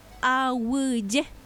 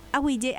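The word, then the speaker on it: aguije: Y es Awiye. Daja ei Awiye. Awiye, Awiye. Por todos lados, Awiye, Awiye. Pero para que diga Awiye, tiene que ser A-G-U-I. Y latina. Pero se escribe, en guaraní se escribe A-G-U-I.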